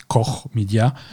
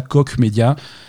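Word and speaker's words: Koch Media: c'est pas Coche Media, c'est pas c'est Core Media. – Mhm. – et Désolé pour nos amis américains. Nos amis américains, ils prononcent ça coq média, ce qui est plutôt malheureux.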